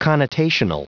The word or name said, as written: connotational